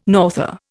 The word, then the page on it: norther: Indian